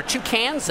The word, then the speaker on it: toucan: I think you're putting the accent on the wrong syllable when you say toucan. – Toucan. – When you said toucans, I thought there were two cans.